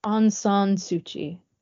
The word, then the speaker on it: Aung San Suu Kyi: And another person that received the same honor was this woman, Aung San Suu Kyi. Is that how you say her name? – Aung San Suu Kyi.